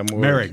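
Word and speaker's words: Moehrig: They took, how do I say it, Morick? – Yeah, Merrick. It's Merrick.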